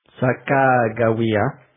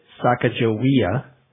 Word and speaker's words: Sacagawea: The closest is probably something like Sacagawea. Most Americans pronounce it Sacagawea, which is almost certainly not correct.